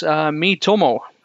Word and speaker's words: Miitomo: And it's Miitomo.